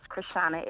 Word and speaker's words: Krishawna: My name is Krishana.